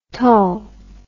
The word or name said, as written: Tull